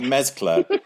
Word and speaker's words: Mezcla: And that's, with my pronunciation difficulties at the start of this, is it Mezcla? – Yes, that's all correct so far.